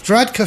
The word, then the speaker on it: Varadkar: Then it was, I always get his name wrong because it's like Faruka, but I shouldn't be... – Varadkar from Southern Ireland.